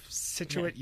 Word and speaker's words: Scituate: Scituate, you sit with it, with Scituate.